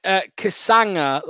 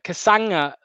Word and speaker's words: Kasanga: Ben says it's pronounced Kasanga, like lasagna. Kasanga.